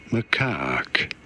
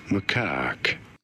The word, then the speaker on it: macaque: Macaque. You do want to stress on the second syllable, on the caque syllable. Macaque.